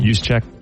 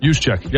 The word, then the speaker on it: Juszczyk: Juszczyk. – Juszczyk.